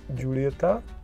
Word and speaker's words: Julieta: Všiml jsem si, že písmeno J vyslovujete anglicky J, ale správná španělská výslovnost je H. Jak Takže jsem už, Romeo jak jsem, i jak... – Julieta je Romeo i Julieta, Joya de Nicaragua a tak dále a tak dále.